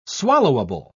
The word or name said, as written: swallowable